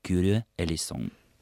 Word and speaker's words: Kyrie eleison: Действительно, я ошибся и неправильно произнес греческое словосочетание «Господи помилуй». Правильно оно произносится «Kyrie eleison».